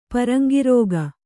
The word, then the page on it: ಪರಂಗಿರೋಗ: paraŋgi rōga